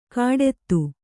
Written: ಕಾಡೆತ್ತು

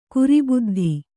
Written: ಕುರಿಬುದ್ಧಿ

